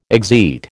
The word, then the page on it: exeat: American English